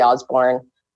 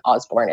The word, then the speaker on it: Osborne: And then I'm also on Facebook, which is Shelby Osborne. And it'll be in the show notes, my name, I'm sure, but it's spelled EY. And then Osborne is OSBORNE.